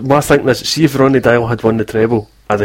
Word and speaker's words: Deila: Ronny Deila.